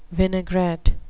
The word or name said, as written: vinaigrette